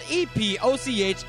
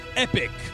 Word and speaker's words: epoch: Get a time machine, the default name of which is spelled E P O C H and is pronounced epic, epic fails. In American English and in British English, it's pronounced epoch.